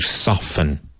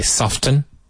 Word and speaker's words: soften: Ablandar, to soften. Now, this is like often. You can say soften, but I think more common is soften, where the T is not pronounced. S-O-F-T-E-N.